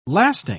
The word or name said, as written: lasting